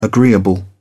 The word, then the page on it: agreeable: British English